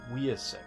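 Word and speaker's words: Wiacek: and it's a Carmine Infantino art with Bob Wyack? – I think it's Wyack? I think it's Wyasek. I think that's how you say it.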